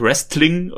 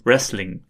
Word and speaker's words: Wrestling: Und hier habe ich ja mal von Philippa Jahrke gelernt, die korrekte Aussprache von Wrestling ist komplett ohne T. Und er sagt es komplett richtig hier. Mm. Also nicht Wrestling oder sowas, sondern oder auch nicht Wrestling, sondern eben so Wrestling.